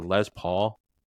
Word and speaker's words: Les Paul: was it a Les Paul? – Yep.